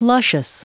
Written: luscious

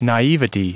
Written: naivety

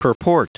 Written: purport

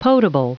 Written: potable

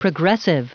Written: progressive

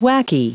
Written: wacky

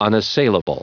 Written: unassailable

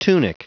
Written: tunic

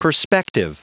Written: perspective

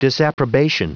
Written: disapprobation

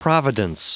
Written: providence

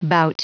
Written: bout

